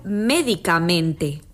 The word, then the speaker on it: médicamente: Quizá les resulte más claro observando el contraste que se produce entre el nombre medicamento y el adverbio médicamente.